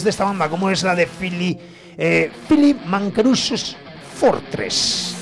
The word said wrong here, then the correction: Mancaruso: No. Sí, Mancaruso, ahí lo he dicho bien.